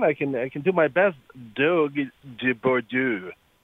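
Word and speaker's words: Dogue de Bordeaux: So I'm not a lot better with the pronunciation than you are, but it's the Dog de Bordeaux.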